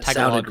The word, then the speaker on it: Tagalog: I don't know if I pronounced that properly, but I'm sorry if I didn't. – It's close enough. I work with a lot of people from Philippines, and that Tagalog. Sounded right. – Yeah. – That sounded right to me, but I'm not an expert.